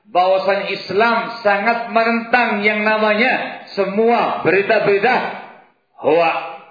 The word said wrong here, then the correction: hoax: Gimana bacanya? Hoak apa? Hoak apa? Hoax. Hoax, ya Allah.